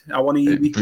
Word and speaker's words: Awoniyi: Awoniyi? – Yeah, it is now.